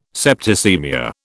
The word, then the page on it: septicaemia: British English